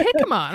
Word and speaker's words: jicama: And people were like, "It's jicama."